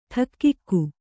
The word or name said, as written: ಠಕ್ಕಿಕ್ಕು